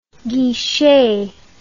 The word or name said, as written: guichet